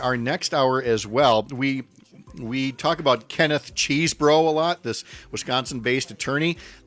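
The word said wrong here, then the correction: Chesebro: We learned from a Washington Post profile yesterday that it's actually Chesbro, so we'll try to say it right,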